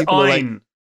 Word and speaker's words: Ayn: Also, it's Anne. Like, it's Ayn. – Like, but people pronounce it Anne, don't they? – It's Ayn Rand.